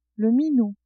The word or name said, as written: minou